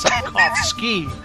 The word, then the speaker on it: Sapkowski: I've seen some people, they pronounce the W with a V, so it's Sapkowski now, Ross.